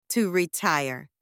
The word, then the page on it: to retire: anglais